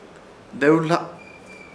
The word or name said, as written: Dewhla